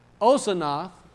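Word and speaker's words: Osanath (אָסְנַת): And so this story that Osanath, which would be how you say it in the Hebrew, which is representative to a bush, Dinah has a daughter, Osanath, and the brothers wasn't going to have that.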